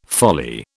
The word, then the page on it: folly: British English